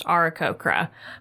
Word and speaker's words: Aarakocra: And the dulcet voice of Matthew Mercer told me that it is pronounced Aarakocra, but I did not hear him say it.